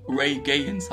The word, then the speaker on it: Ray Gaines: Und ähm, halt geht es dann um den Rettungshubschrauber, der halt ähm, der Ray Janis, weiß also, er, wie heißt er, wird er so ausgesprochen? Warte, wie heißt J der? Hier? Ray Gaines heißt Ray Gaines, ja.